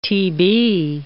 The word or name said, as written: Tb